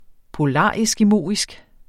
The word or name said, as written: polareskimoisk